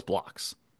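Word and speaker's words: Blockx: Is it Block or Blocks? – I think you pronounce the X, yeah. – You pronounce the X, so it's Blocks.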